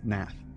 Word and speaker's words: Pnath: It's spelled P-N-A-T-H, which I believe is pronounced Nath.